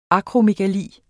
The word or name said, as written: akromegali